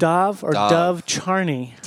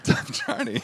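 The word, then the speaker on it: Dov Charney: It's, how do you say his name? – Dove Charney. – Dove Charney.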